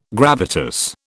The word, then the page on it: gravitas: British English